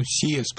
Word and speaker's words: CES: Ну, CES правильно произносится.